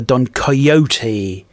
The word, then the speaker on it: Don Quixote: They might be just still going, but they did a song about Don Quixote. Now this is I think going down the Don Coyote road of pronunciation.